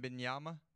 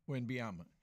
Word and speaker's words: Wembanyama: How you pronounce it again? Uh, pronounce that Windiana, name for him. Wembanyama. – Wembanyama.